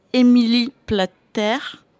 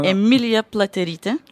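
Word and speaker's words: Emilija Pliaterytė: En français, je crois que ça se prononce, je vais essayer, c'est Émilie Platère. Ou okay. Emilia Platerite en lituanien. – Dis-le en lituanien. – Emilia Platerite.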